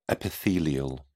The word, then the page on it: epithelial: British English